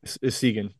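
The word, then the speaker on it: Essegian: And the Connor Segan, how do you say his name? – Segan, yep.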